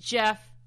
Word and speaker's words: Geoff: I literally didn't know that it was supposed to be pronounced Jeff, and I showed it to Jonathan, and I'm like, yeah, this girl has a boyfriend.